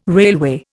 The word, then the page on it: railway: Indian